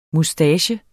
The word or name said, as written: moustache